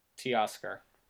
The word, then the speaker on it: Teoscar: Yeah, but is that how you pronounce it? Toscar? – Teoscar. – Oh, Teoscar. Because if it was Toe, it'd be T-O-E.